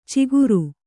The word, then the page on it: ಚಿಗುರು: ciguru